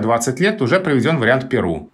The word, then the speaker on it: Перу: получил распространение вариант «Перу», близкий к языку-источнику, и он закрепился в русском языке.